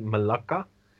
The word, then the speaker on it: Malacca: and Malacca, as it is pronounced properly, Malacca.